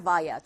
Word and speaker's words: via: via or via. 둘 다 발음 가능하고요.